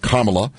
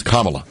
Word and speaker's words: Kamala: Not Kamala, but Kamala. Doesn't really matter. – Is it Kamala or Kamala? – It's Kamala. – Kamala. – Kamala. – Mm-hmm. – That's the correct pronunciation.